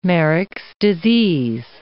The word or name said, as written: Marek's disease